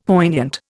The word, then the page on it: poignant: Indian